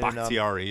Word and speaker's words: Bakhtiari: What was it, Bakhtiari? Did I say that right? As an offensive lineman. – Bakhtiari, yep.